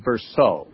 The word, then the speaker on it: Bercot: I have a book here called The Kingdom That Turned the World Upside Down by David Burkhardt. Do you know that book? – Yeah, I think his name might be pronounced Bersault, David Bersault. – B-E-R-C-O-T.